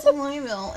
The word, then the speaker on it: Louisville: It's Louisville, it's Versailles. Go to France.